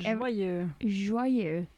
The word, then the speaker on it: joyeux: I cannot for the life of me pronounce joyeux. Like joyeux anniversaire. Yeah. I cannot say it. Like joyeux. That was really good.